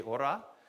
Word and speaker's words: hora: We pronounce it with a silent H. We say hora.